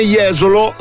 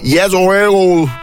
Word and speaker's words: Jesolo: No, non pronunci bene Jesolo se non lo pronunci Jesolo. Cioè, deve esserci questo finale che ti spinge già alla festa.